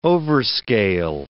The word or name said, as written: overscale